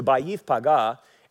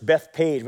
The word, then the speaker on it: Beit Pagah: Now, when it says in Matthew 21, it says this: now when they drew near Jerusalem and came to Beit Pagah at the Mount of Olives. Now, people say this in English, Bethpage, but that's not how you really pronounce it. It's pronounced Beit, which means house, and Pagah is unripe figs.